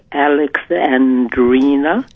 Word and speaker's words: Alexandrina: Five syllables. Alex. – Yes. – Andrina. – Alexandrina. – There you go. Boy, that's did you go? Beautiful. – I thought I did the five syllables. No? – No, you did six.